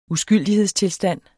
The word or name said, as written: uskyldighedstilstand